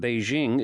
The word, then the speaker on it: Beijing: This was entirely logical, in the sense that Beijing is the correct pronunciation of the name in Mandarin Chinese.